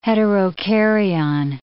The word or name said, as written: heterokaryon